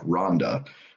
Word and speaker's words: Rwanda: And they actually don't call it Rwanda. It's not like a separate syllable. It's more like Rwanda.